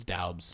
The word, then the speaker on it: Doubs: I'm never gonna pronounce it Dubs. It's too cool. It's Doubs.